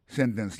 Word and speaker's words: sentence: Ça, nous t'a dit dans sentence, c'est que l'accent tombé sous sen, sous premier syllabe là. Sentence completion. Pour completion dans lui-même, accent tombé sous deuxième syllabe là.